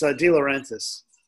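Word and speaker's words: De Laurentiis: DeLauren, how do you say that? DeLauren? – No, it's DeLaurentis.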